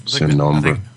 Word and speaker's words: Sin Nombre: The highlights for me were Sin Nombre, or I guess that may be the pronunciation, Sin Nombre. – I think that's right, yes.